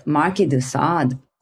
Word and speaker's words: Marquis de Sade: And thinking about fantasies and pleasure means Marquis de Sade was out there.